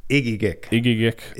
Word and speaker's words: Egegik: Egegik, Alaska.